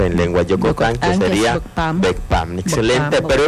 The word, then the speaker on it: Bokpam: Pero su pronunciación es la que hace la diferencia. Se pronuncia Ugh. Entonces, por eso es Bokpam.